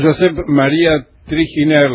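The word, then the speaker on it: Josep Maria Triginer: Estamos hablando con Josep María Triginer, lo digo bien, ¿no? ¿Está bien dicho? – Lo dije usted muy bien.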